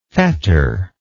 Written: fetterless

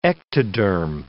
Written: ectoderm

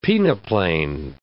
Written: peneplane

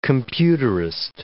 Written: computerist

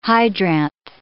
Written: hydranth

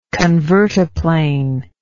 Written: convertaplane

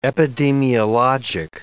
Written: epidemiologic

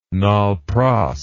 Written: nol-pros

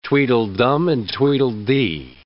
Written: Tweedledum and Tweedledee

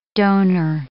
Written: donor

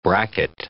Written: bracket

